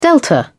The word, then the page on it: delta: British English